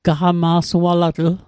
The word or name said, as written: gaqama'sualatl